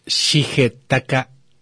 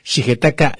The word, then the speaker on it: Shigetaka: En el 99, como les decía, Shigenat, no, Shigetak, mm, es que estos nombrecitos, Shigetaka, sí, sí lo dije bien ahora, Shigetaka Kurita,